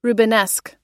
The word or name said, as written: rubenesque